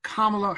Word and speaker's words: Kamala: Petty, so it's Kamala, the accent on the first syllable, Kamala Harris, again, the vice presidential pick for Joe Biden.